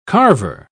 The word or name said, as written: carver